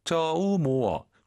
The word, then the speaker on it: 周末: 이 뜻의 특성상 쪼모愉快 이렇게 안 하죠. 다시 한번. – 周末愉快. – 오유는 어예요. 쪼가 아니라 쪼, 모가 아니라 모어, 쪼우모어.